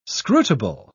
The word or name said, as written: scrutable